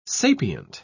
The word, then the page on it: sapient: British English